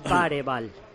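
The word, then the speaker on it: Bergvall: La pronunciación buena. De ese chaval es Bareval. – Ah, Bareval, perdona.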